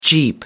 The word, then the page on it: jeep: American English